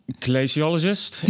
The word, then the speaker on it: glaciologist: Climatologist glacial zeg ik dat goed? Gle glaciolist. Nee, glaci glaciologist in het Engels, glacioloog in het Nederlands.